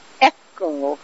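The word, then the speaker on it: E'cco: The restaurant that we're checking out right now is called Echo, although it's spelled E apostrophe C-C-O.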